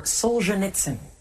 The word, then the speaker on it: Solzhenitsyn: No, this is a tricky one to pronounce. Looking for Solzhenitsyn.